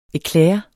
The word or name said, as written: eclair